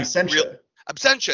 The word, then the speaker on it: Absentia: I always forget that he did Absentia, which was actually Absentia.